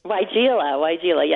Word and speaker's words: weigela: What about, how do you say it, the weigela? – Weigela, yeah. So people don't know how to pronounce that plant, but it is weigela.